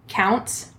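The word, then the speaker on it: Kountze: So he shot Christine Baker while she was knitting and watching TV in her home in, I'm probably going to say this wrong, but it's Counts, Texas. Which is close to the Beaumont area. – Can you spell it? – K-O-U-N-T-Z. – Okay. – Yeah. – Yeah, I think you said it right.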